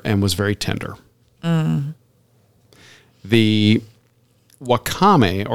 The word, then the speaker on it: wakame: I don't know how to pronounce it. – Wakame? Yeah.